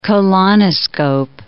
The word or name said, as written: colonoscope